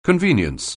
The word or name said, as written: convenience